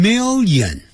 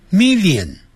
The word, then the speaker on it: million: 那一百万呢，好，这个时候啊我们就要念成 one million，那个 million 很多人会念成 million，念成 million， million 的音不对，在美式音的时候啊要念成没有眼睛，没有眼 million million million，它不会连在一起 million，不是这样念的 million million one million，或者是 a million a million。